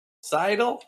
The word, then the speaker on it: sidle: I'll say a guy saddles up next to you. Sidle? What? How do you say that? – My love. Yeah, sidle. – Saddle. – No, sidle up to somebody. – Sidle? – Yeah, S-I-D-L-E.